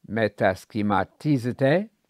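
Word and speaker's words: metaschematizete (μετασχηματίζεται): A word study on the verb Paul used, metaschematizete. I'm using the modern Greek pronunciation there, incidentally.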